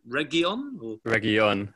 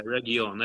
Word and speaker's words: Reguilón: How do you say his surname? I probably should know this. I'm having a bit of a Paul Merson moment here. What is it? Reguilón, I think. – Yeah, Reguilón.